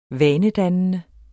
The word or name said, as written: vanedannende